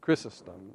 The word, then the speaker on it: Chrysostom: We go back and, and look at something that I, I pronounce it Chrysostom, okay? Chrysostom is the way it's pronounced, but I like Chrysostom better. It's easier for me to say.